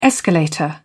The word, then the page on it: escalator: British English